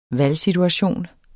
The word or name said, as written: valgsituation